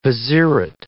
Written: vizierate

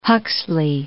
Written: Huxley

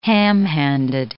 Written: ham-handed

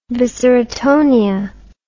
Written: viscerotonia

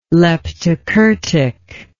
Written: leptokurtic

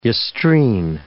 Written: yestreen

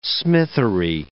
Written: smithery

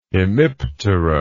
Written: hemiptera

